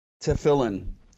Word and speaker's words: Tefillin: Tefrium? – Tefillin is the name for them. – Yeah, what? – Oh, Tefillin. – Teflon? – Tefillin. It's all right. We don't need to pronounce it right.